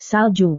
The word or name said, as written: salju